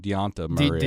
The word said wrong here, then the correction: Dejounte Murray: DeJounte Murray.